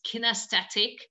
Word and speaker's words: kinesthetic: I think the correct pronunciation is kinesthetic in English,